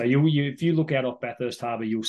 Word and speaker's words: Bathurst: fun fact about Bathurst Harbour, and I know, Bathurst Harbour, did I say that right? Yeah. – Yeah, like Bathurst, the racetrack.